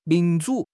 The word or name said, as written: 民主